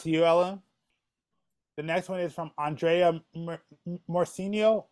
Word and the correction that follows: Moreno: No, that's Moreno.